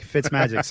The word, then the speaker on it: Fitzmagic: Ryan Fitzmadrick. Get him into your lineups. What else? – Madrick? – Magic. Fitzmagic. Sorry.